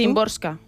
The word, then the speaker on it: Szymborska: Szymborska.